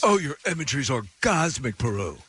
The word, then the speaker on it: Perou: I guess that's how you say his name. P-E-R-O-U. – Peru.